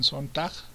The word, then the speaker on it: Sontag: El texto se llama Ante el dolor de los demás, de Susan Sontag.